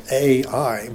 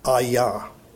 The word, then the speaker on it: Ai: But I don't know exactly how it got translated or transliterated into English as AI. But, well, that, anyway, has nothing to do with the story, but I just thought it was interesting. But the name of the town is pronounced Ai or Aya.